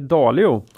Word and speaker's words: Dalio: Jag har hedgefondförvaltaren, miljardären och citatmaskinen eh, Ray eh, Dalio.